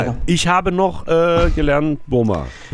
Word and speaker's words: Burma: Oder Burma, also im Englischen wird es ja gleich ausgesprochen, Burma.